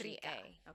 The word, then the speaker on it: Shtriga: It's spelled like the S-H-T-R-I-G-A. Striga. – Okay, so I'm saying it opera and I should. – We're white people. We just say Striga.